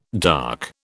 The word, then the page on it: dark: British English